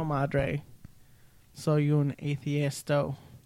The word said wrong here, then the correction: ateo: Ateo. That's not how you say it.